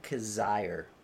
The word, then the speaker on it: Kizzire: But my the guy I'm looking for this week is Patton Kizzire. Still don't know if I pronounced that last name right, but I think you actually got it right. I think for the first time, you executed that perfectly.